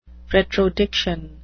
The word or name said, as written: retrodiction